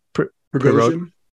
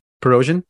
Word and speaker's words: Prigozhin: Prigozhin? Prigozhin.